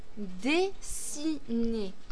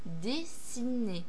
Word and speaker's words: dessiner: Dessiner. Il y a trois syllabes. Dessiner. Le I ne se prononce pas I, mais I.